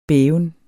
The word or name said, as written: bæven